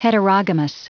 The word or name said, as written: heterogamous